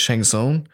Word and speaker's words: Shang Tsung: You're going to play as Shang Tsung or Spawn. Or very good pronunciation.